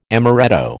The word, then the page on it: amoretto: American English